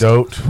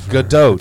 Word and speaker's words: Gadot: Gadot. I don't think it's going to do. – The T is pronounced. – Gadot. – Whatever. – It's Gadot.